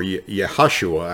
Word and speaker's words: Yehoshua: Now, Hoshea, or Hoshea, or Yehoshua, actually is how it's pronounced in Hebrew, was his name originally, but Moses changed his name or called him Yeshua in Hebrew.